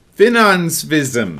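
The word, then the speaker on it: Finansavisen: This is a Norwegian newspaper known as... Finansvism. – All right. – That's how that's pronounced exactly.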